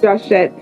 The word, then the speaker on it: Joshett: Yep, it's Joshett.